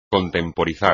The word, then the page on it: contemporizar: espagnol